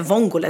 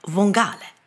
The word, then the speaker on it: vongole: Och de heter vongole, det är liksom bebismusslan. Men det här har inte Gillis riktigt fattat, så att han uttalar vongale istället.